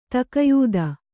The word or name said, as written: ತಕಯೂದ